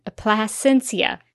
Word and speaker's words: Plasencia: I actually looked up an interview with Plasencia and discovered that that was how you pronounced his name. So that I don't butcher it every time, because I feel like I was always guessing, because I was. So now I know it is Plasencia.